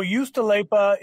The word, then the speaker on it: Juustoleipä: So Juustoleipä is the cheese.